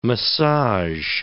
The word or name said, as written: massage